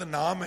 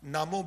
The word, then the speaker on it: नामो: आम तौर ते शब्द नाम है, नामो बहुत घट है, इहदा उचारण नाम नहीं करना, नामो करना है। औंकड़ साइलेंट करनी है, होड़ा बुलाना है।